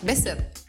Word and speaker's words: Bessette: Geneviève Bessette, je le prononce comment? Bessette? Bessette? – Bessette. – Bessette, OK, comme la cycliste, finalement.